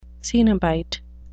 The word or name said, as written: cenobite